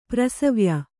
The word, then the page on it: ಪ್ರಸವ್ಯ: prasavya